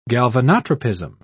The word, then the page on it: galvanotropism: British English